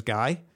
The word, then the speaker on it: Guy: My name is Guy. It's spelled G-U-Y.